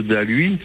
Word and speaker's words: Daluis: Et il y a les deux gorges qui sont parallèles, la gorge du, les gorges du Science et les gorges de Dalui. Et le Science, on prononce le S, et Dalui, on ne le prononce pas.